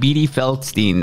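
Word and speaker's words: Beanie Feldstein: starring Caitlin Denver and Beattie Feldstein.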